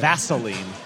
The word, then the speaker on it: Vaseline: How do you say it? – Vaseline. – It's an S. – Yeah, there's no Z.